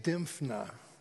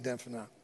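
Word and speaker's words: Dymphna: Saint Dimphna, a young Irish girl who was martyred by her own father in the 7th century.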